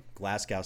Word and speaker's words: Glasgow: Is with us from Glasgow, Scotland. Say hi, Glasgow. – Glasgow. – You're pronouncing it properly. You're not pronouncing it the American way.